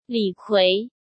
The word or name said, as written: Li Kui